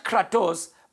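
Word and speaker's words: kratos (κράτος): It is the Greek word spelled K-R-A-T-O-S, pronounced kratos.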